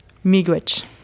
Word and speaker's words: Miigwech: I think it's pronounced Miigwech. – Miigwech.